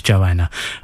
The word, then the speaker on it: Joanna: Before that was Scott Walker, Wonderful Joanna. I did say it right in the first place, Joanna.